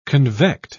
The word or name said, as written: convect